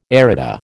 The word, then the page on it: Arita: American English